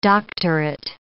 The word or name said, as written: doctorate